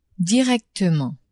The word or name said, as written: directement